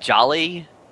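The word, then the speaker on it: Djali: Does anyone know how to pronounce the goat's name? Because is it Jolly? – Yes. – Okay. Wow. Because it doesn't look like Jolly, I'm just saying, when you write it out.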